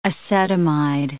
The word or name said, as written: acetamide